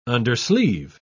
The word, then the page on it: undersleeve: British English